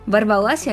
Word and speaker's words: ворвалась: Ворвалась я к вам с новой порцией слов с ошибками в ударениях. Или все-таки ворвалась? Сегодня мы снова поговорим о нормах акцентологии. Лепота или кринж? Понимаю, что порой внутренний голос хочет сказать «ворвалась», но мы не на битве экстрасенсов, поэтому нужно запомнить правило, которое гласит, что большинство глаголов женского рода прошедшего времени имеет ударение на последний слог. И для запоминания стишок. Капель по крышам понеслась, весна к нам в город ворвалась.